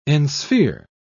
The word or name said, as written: ensphere